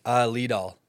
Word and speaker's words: Liedahl: Liedahl.